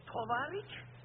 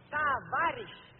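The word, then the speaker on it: tavarish (товарищ): Tovarich? What kind of Russian is that? The word is tavarish with a. Very simple. O is pronounced as a.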